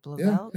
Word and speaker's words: Blauvelt: Well, speaking of community, the curator, and forgive me if I do not pronounce his last name correctly, Andrew Blauvelt. Is it Blauvelt? – Yeah, it's good.